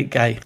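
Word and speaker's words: Ge: Gay spelled G-E, short for Gerhard, so I'm going to say Gay.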